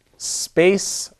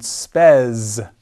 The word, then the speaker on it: spes: The endings, if you simply want to learn the fifth declension endings, which is how I do it, The endings are ace, A-E-A-E-M-A, ace, arum, abus, ace, abus. Another noun of the fifth declension would be space, or as it is pronounced in ecclesiastical pronunciation, spes. But the pronunciation that I learned is classical, space, spei, spei, spem, spe.